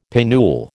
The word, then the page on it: pinnule: American English